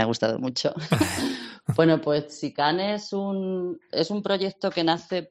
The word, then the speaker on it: Tsikane: Efectivamente, he dicho Sicane, pero efectivamente se deletrea T-S-I-K-A-N-E. Sicane, así pero es Sicane, se pronuncia como una chi.